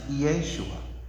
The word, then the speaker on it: Yeshua: in the original language, both of their names, Joshua and Jesus, both of their names are pronounced Yeshua.